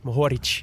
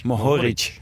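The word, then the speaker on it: Mohorič: Ja, ik kende eigenlijk Matej Mohoric. – Mohoric. – Mohoric.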